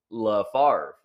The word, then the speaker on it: LaFavre: Is it LaFavre, by the way? – It is. LaFavre. You said it correctly.